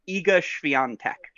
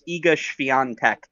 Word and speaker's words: Świątek: You have to check Deuteronomy chapter 3, verse 19, where you can find the official pronunciation, Iga Świątek. So you sneak the N in before you get to the tech. It's Iga Świątek.